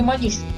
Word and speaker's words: Мониш: Нейрохирург Антонио Эгош Мониш, открывший, или Мониш, все-таки правильно ударение, португалец, открывший терапевтическое воздействие лоботомии при некоторых психических заболеваниях, даже удостоен из этого Нобелевской премии, родился 150 лет назад.